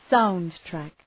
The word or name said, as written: soundtrack